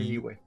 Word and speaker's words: Liyue: se dice Liyue.